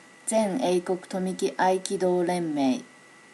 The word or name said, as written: zeneikokutomikiaikidorenmei